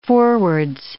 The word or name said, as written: forwards